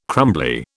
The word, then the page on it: crumbly: British English